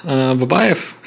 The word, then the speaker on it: Babeyev: I'm Alex, and in Russian it, you can call me Babeyev. It's very hard to pronounce in English, I know.